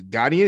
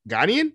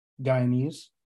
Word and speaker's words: Ghanaian: She is Ghanaian. Is that how you say it? Ghanaian? Is that how you say that? – Guyanese. – No, not Guyanese. She's probably from Ghana.